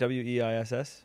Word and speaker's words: Weiss: That's how you pronounce W E I S S.